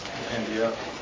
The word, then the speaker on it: compendia: Is that a real word, compendiums? – Compendium. – Compendiums. – Mm-hmm. – Compendiums. – Compendia. – A compendia. – Plural. – Compendia, okay. So the first major halakhic compendium is, did I say it right? – Yes.